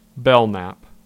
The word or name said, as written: Belmont